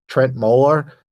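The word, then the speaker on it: Trent Møller: This UK guy, I think he pronounces his name Trent Moeller, just did this crazy, really stripped down mix of it.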